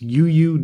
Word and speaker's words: Youyu: It's called U-U. Y-O-U-Y-U. So it's U-U Noodle Bar, and highly recommend it.